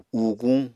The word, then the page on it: uuguñ: Apatani